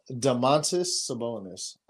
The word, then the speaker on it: Domantas Sabonis: Jaylen Brown, Paul George, Giannis, Domantas Sabonis. That's how you say it. – Domantas Sabonis.